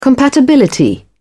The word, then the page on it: compatibility: British English